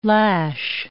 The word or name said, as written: lash